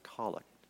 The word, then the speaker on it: collect: And it's spelled like collect, but it's pronounced colic.